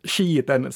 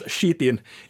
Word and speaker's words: Skiten: Och då var topp tre Rullepung, utanför Björneborg, följt av Musblötan i Borgo, Och sen skiten ska väl uttalas skitin i malax.